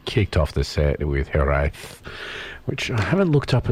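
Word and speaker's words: Hiraeth: Well, I can tell you that Hereth. – Yes. – Is the way to pronounce it. Hereth.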